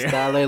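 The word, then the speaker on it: Dalle: You have to say Beatrice Daye like Pitbull.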